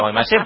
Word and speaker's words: Guaymallén: Guaymallén nunca llegó la tilde. Se pronuncia Guaymallén, pero en el envoltorio no tiene la tilde.